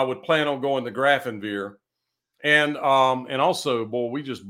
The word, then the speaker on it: Grafenwehr: It's, it's not pronounced any way like it's spelled, and it's got a W in it. It's not Wiesbaden. It is. Grafenwehr. That's it. Grafenwehr.